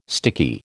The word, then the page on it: sticky: American English